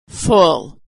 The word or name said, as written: full